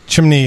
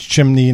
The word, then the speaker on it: chimney: Chimney has two syllables. It's chimney, not chimney.